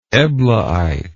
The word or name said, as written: eblaite